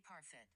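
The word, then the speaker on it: Parfitt: How do you say her name? She was also, Judy was also in Girl with the Pearl Earring. Oh, such a great actress. She's still with us. – Judy Parfit.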